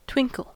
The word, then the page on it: twinkle: American English